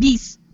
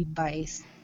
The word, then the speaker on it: Bice: every time na itatry ko i-explain sa tao na BC yung pronunciation niya at hindi Vice, na nako-confuse sila kasi ang spelling ng brand na meron ako ay B-I-C-E. – B-I-C-E.